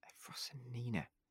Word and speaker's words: Efrosinina: Is it not Maria Efrosina? – Efrosinina. Efrosinina. Yeah.